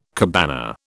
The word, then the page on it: cabana: British English